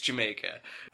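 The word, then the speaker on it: D'yer Mak'er: But he had House of the Holy, Rock and Roll, Ramble On, Jamaica, which is spelled Dire Maker, but like any Led Zeppelin fan worth their salt knows. – You could get in a fight over the pronunciation. – I definitely have almost gotten in a fight. – I would throw down. It's Jamaica.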